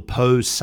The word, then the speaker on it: Pose: It's called the Pose. That's how they pronounce it. It's spelled P-O-S-E.